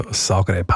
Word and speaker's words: Zagreb: Ich weiss, welche Richtung du willst, aber die spricht man eher mit S aus. Also Zagreb, Hauptstadt von Kroatien und eine riesige Fußballstadt. – Schreibt sie mit Z und sagt Zagreb.